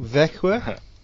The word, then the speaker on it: Växjö: Växjö.